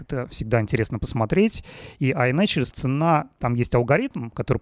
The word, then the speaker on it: iNaturalist: Да, ну вот эта программа iNaturalist, да, вообще так правильно произносится,